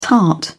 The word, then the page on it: tart: British English